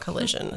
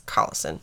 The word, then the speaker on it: Collison: The salesman's name was Merle Collison. It looks like collision that's spelled wrong, and I had to keep, like, reminding myself, no, it's not collision, it's Collison.